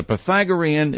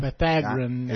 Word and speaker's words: Pythagorean: All right, the Pythagorean is, I hope that's the way you pronounce it. Maybe somebody will correct us. It is. – That's it. – Is that Pythagorean? – Pythagorean.